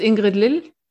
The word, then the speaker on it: Ingrid Lill: No, I'm actually originally German. So it's it's Ingrid Lill.